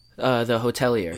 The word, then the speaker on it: Hotelier: How do you pronounce the middle band's name again? Can you say it again? – The Hotelier. – Hotelier. Right.